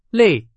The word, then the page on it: 类: Chinese